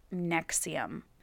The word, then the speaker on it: NXIVM: It has really weird spelling N X I V M, but it's pronounced Nexium.